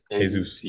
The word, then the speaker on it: Jesus: Jesus, that's how we pronounce his name. Jesus.